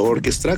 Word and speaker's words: Orchestra: Por supuesto, em, estamos en todas las redes sociales como arroba Maiden Orchestra con CH o Orchestra, como se pronuncia en inglés.